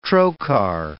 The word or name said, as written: trocar